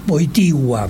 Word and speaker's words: Wojtyła: Carol Wojtyła, muy bien pronunciado en polaco, dicen.